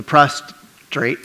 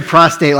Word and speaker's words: prostrate: So the prostrate. It's your fault. I know. He said prostate like three times, and I never, ever have been able to say it right again.